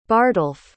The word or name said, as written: bardalph